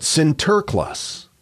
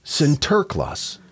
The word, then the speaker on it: Sinterklaas: It's derived from how the Dutch immigrants of New Amsterdam pronounced his name. Not St. Nicholas, but Sinterklaas. – Oh. – Sinterklaas.